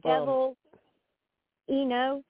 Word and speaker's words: Devaleno: Yeah, so okay, that's like Devil Eno. – Oh, excuse me, spell it. D-E-V-A-L-E-N-O, like Jay Leno, like Leno. So Devil Eno, yeah.